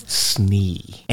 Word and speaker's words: Snee: Snee. And I think it's spelled exactly how it sounds. Yes. There's S-N and then about 37 E's.